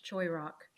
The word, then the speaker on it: Shyrock: The pronunciation is Shyrock? – I pronounce it with a hard CH, Shyrock.